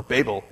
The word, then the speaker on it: Babel: Or Babel is how it's supposed to be pronounced.